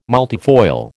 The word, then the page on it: multifoil: American English